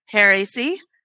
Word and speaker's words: Hare AC: We're partnering with Hare AC.